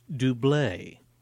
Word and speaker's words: Double: It's spelled double, but it's pronounced Dublé.